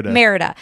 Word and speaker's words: Merida: Merida.